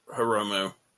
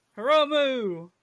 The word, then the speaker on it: Hiromu: Haruma Takahashi. Defending against Taiji Ishimori, a.k.a. Bone Soldier. – I'm going to say Hiromu. – Hiromu!